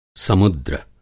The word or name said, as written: समुद्र